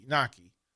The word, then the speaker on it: Knoche: And the way it's spelled, K-N-O-C-H-E, Nockey.